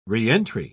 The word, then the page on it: reentry: British English